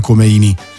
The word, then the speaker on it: Khomeini: Che all'epoca, io sono nato e cresciuto con l'Ayatollah Khomeini, nel senso che all'epoca si parlava solo di lui, l'Iran era Khomeini, con l'accento sulla I.